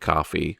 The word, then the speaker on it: Ko-fi: like Ko-fi, which I guess is pronounced coffee, and LibraPay, which is as fun and strange a payment service as I've ever seen.